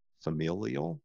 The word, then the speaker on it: familial: And I want to know like is there any cultural or familiar familial? Yeah, that's the better way of pronouncing that word um, connection to your last name?